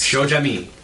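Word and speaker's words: Shoja'my: S-H-O-J-A. – Okay. – Apostrophe M-Y. – How is it pronounced? – Shojami.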